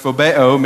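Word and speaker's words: phobeo (φοβέω): It's pronounced phobeo, meaning to fear. We get our English word phobia from the same word.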